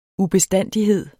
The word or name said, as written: ubestandighed